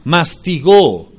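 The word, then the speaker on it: Mastigó (μαστιγόω): Azotar proviene del griego mastigó, con doble O, así como se oye. Mastigó, perdón, el acento lo lleva en la primera O. Mastigó.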